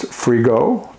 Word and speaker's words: Frigault: That's F R I G A U L T, pronounced frigo, like free to go.